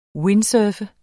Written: windsurfer

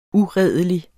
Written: uredelig

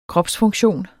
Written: kropsfunktion

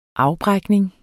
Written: afbrækning

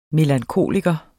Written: melankoliker

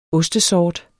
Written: ostesort

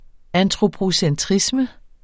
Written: antropocentrisme

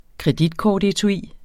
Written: kreditkortetui

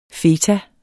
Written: feta

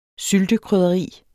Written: syltekrydderi

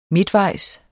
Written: midtvejs

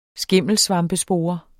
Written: skimmelsvampespore

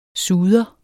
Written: SUDEREN

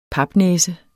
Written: papnæse

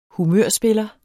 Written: humørspiller

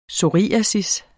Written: psoriasis

